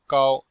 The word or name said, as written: gao1